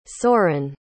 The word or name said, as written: Soren